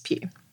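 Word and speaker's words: Pugh: Um, but then, and then there's this whole conversation about is Florence, is it pronounced Pew? Yeah, Florence Pew.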